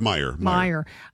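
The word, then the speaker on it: Meijer: In Michigan, CBS News projects John Gibbs defeats incumbent Representative Peter Major, I'm not sure. – It's pronounced Meyer.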